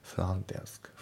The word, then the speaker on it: front desk: まあ、あと発音ですけど、フロントの T が抜けますね。フロントデスク。